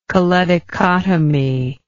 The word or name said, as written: choledochotomy